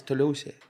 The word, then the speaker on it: Toulouse: Toulouse, en el sur de Francia, eh, pues está investigando. – Toulouse. Toulouse. Toulouse. – Es que, Es que no se pronuncia así, es Toulouse.